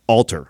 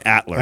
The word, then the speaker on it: Alter: we figure out how to pronounce the last name? – Alter. – Alter. – Yeah, yes. I was saying Atler.